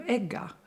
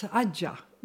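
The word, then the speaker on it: egga (عجة): And it's in Egypt we call them egga. – In in Lebanon it's ajah. We pronounce the ja ga.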